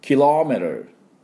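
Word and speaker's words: kilometer: キロメートルで、メートルですから、メートル、 M-E-T-R ですから、メートル、メートル、キロメートルが正しいキロメートルの音です。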